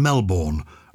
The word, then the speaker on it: Melbourne: We've been listening to your podcast for some time here in Melbourne, pronounced Melbourne here in Australia.